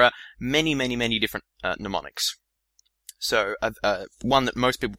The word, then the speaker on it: mnemonics: I want to briefly mention mnemonics. Now, if you want to Google this term and you haven't heard of it before, be wary that it starts with M. It's spelled M-N-E-M-O-N-I-C-S. Mnemonics.